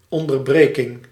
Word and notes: hiatus: /haɪ.ˈeɪ.təs/

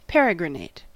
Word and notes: pérégriner: France; IPA: /pe.ʁe.ɡʁi.ne/

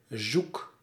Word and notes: lost: /lɔst/; [lɔːst]; /lɒst/; /lɑst/

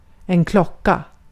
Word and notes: klocka: /ˈklɔ̀ka/

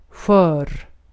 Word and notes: brittle: [ˈbrɪ.tᵊl]; /ˈbɹɪtl̩/

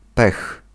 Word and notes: pech: [pɛx]

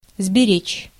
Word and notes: conserver: France; [kɔ̃.sɛʁ.ve]

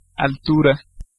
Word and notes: pitch: /pɪtʃ/

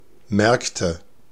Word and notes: marché: /maʁ.ʃe/; /maχ.ʃe/

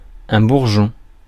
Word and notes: button: /ˈbʌtn̩/; /ˈbʌtən/; [ˈbʌʔn̩]

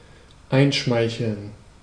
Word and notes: ingratiate: /ɪnˈɡreɪ.ʃi.eɪt/

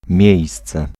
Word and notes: emplacement: [ɑ̃.plas.mɑ̃]; France

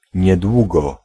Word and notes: soon: /suːn/; [suːn]

